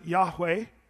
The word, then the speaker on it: Yahweh: And so some people, usually it's the Hebrew scholars that pronounce it Yahweh, putting the YHWH, and then sort of what we would do is put vowels in there to try to make a speakable word.